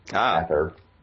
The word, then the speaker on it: Aether: You know, right, the AE is pronounced as, like, short A, like ran, or bad, Aether.